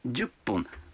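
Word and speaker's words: джупун (十分): А 10 минут – джупун. Вы заметили, что когда фун добавляется к 10, дю, то произносится пун? – Послушайте и повторите каждую фразу.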